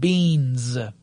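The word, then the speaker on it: Beenz: So the two companies I want to talk about specifically are Flooz and Beans. Flooz is spelled F-L-O-O-Z, and Beans, B-E-E-B-E-E-N-Z.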